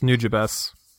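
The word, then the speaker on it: Nujabes: It's actually pronounced New Jabes. – Are you freaking kidding me? – I'm not even shitting you, bro. – No way. – Yeah. – Really? – Yeah, there's a song. There's a really old song that he did early on, and he's like, for some reason, he just says New Jabes at the beginning.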